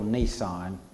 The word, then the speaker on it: Nisan: It's pronounced just like the vehicle Nisan.